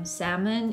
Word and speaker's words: salmon: Ну, а тем временем наш лосось и миндаль, они все пишутся с буквой Л, но, они, но она не произносится. То есть мы говорим salmon и almond. – Да, не сальмон и альмонд.